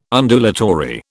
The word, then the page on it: Undulatory: British English